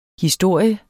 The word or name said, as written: historie